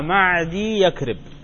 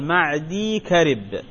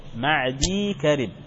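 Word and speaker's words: معديكرب: وخطأ أن تقراها معدي يكرب، لا مش معدي يكرب، هي يا واحدة تمدها ثم مباشرة تنتقل إلى الكاف، تقول معدي كرب، هكذا النطق الصحيح، معدي كرب وليس معدي يكرب، بعضهم يخطئ.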